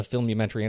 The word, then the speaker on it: Filmumentary: Before we get started, could you tell us a little bit, for those who haven't seen a Filmumentary, what is a Filmumentary and what makes it stand out over other directors' commentary or behind-the-scenes featurettes? – Well, a Filmumentary, and well done for saying it correctly, is quite a difficult word, but it's quite easy to describe, really.